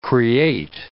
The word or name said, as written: create